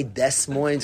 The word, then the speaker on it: Des Moines: Des Moines, you don't say Des Moines because the S is silent.